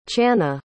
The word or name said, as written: chana